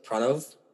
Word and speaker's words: Pranav: Like, my name is supposed to be pronounced, like, Pranav, but I've been saying, like, Pranav, like, as far as I can remember.